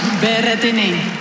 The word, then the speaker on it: Berrettini: Berrettini. – Eh bien, elle l'a bien prononcé.